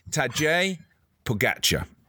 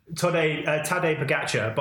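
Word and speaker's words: Tadej Pogačar: Thought it was, okay, Tadej Pogacar. – So I've gone with Tadej Pogacar, but I particularly enjoyed Sean Kelly's pronunciation of it. – Yeah, go on. – Which is Tadej Pogacar.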